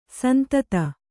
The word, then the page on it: ಸಂತತ: santata